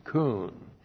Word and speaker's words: kun: First one is kun. K long U N, kun.